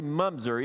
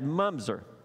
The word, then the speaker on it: mamzer: It's spelled M-A-M-Z-E-R. I thought it was Mamzer. It's actually Mumzer, even though it's M-A-M-Z-E-R. So the Hebrew for that is Mumzer.